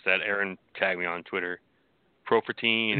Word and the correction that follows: Prefontaine: or Profer... – Prefontaine. – Prefontaine.